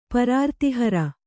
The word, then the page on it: ಪರಾರ್ತಿಹರ: kannada